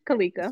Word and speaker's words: Kaleka: Yep, Simran Kaleka.